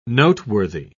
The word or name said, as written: noteworthy